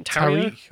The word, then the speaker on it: Tarrio: Tarrio or Tarrio, yeah, the, yeah.